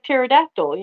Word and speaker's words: pterodactyl: No, but it's like pterodactyl. You know, the P is silent.